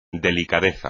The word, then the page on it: delicadeza: espagnol